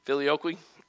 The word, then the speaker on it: Filioque: philoloquy, how do you pronounce that? Filioque and clause,